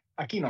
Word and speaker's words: Aquino: Yeah, Aquino.